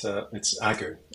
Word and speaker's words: Agu: Joe has an easy name. It's Joe Agu. A-G. It's even, you even spell it like you say it, right, Joe? – Yeah, it's Agu.